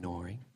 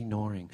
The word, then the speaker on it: gnawing: It's silent G and gnawing. Little snuggling pug, silent G, gnawing.